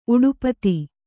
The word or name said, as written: ಉಡುಪಥ